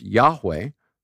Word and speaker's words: Yahweh: The Jewish people won't even pronounce the Tetragrammaton, the official Hebrew name of God, which we think is pronounced Yahweh, right?